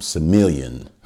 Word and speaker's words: Semillon: Semillon. Some people like to pronounce things the way they want, but Semillon is French, and Sauvignon Blanc.